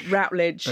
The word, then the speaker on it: Routledge: And Patricia Routledge, how do I say her surname? Is it, I always say it wrong. – Routledge.